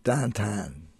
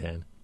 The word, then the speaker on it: downtown: In terms of downtown development, Now, it so happens that in the city of Pittsburgh, a unique city, downtown is pronounced Dantan. So it comes up over and over again. – Dantan.